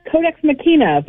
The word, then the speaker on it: Codex Machina: How am I pronouncing that, Heather? Pretty good? – Codex Machina, but I'll take it either way.